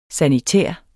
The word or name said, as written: sanitær